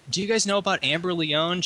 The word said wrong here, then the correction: Amber Lyon: She was fired from CNN because she was repeatedly I think it's Amber Lyon.